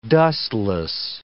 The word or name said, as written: dustless